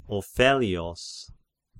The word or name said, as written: Ophellios